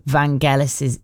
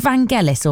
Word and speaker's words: Vangelis: And the ultimate winner's music, no name on this one, has to be Vangelis's. Is it Vangelis or Vangelis? – Vangelis soft, I think. – Vangelis's soft G.